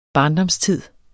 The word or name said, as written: barndomstid